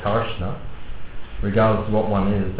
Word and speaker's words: Karsna: K-A-R-S-N-A. Karshna.